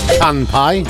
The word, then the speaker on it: Kanpai: K-A-N-P-A-I. – Canpai? – Canpai.